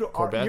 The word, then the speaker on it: Courbet: No, Gustav Courbet. I assume it's Courbet. I assume T is silent as well. – Dude, Courbet.